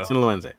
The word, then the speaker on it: Sinaloense: Oh, so they Sinaloense? – Yes. There we go. – Sinaloense.